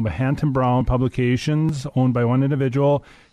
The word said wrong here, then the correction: Hatton-Brown: And did I say that right? I think I did. – No, it's Hatton Brown. Hatton Brown Publishers.